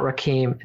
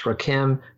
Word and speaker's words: Rakim: And I'm being reminded once again, I'm mispronouncing Rakim. It's not Rakim, it's Rakim, but I've been saying it wrong for 35 years.